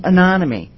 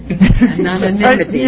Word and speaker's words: anonymity: We took their names off of public records so that they could work at the animal testing labs with some anonymity. I said that wrong. – Anonymity.